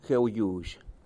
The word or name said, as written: gueuLluzh